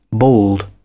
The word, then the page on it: bald: British English